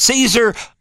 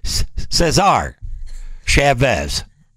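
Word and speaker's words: César Chávez: Is it Caesar or Cesar? Chavez.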